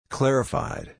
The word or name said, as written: clarified